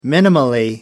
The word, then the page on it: minimally: British English